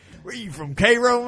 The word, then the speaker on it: Cairo: Spelled not like Cairo. – Cairo. – Spelled right. – like Cairo, but not pronounced Cairo.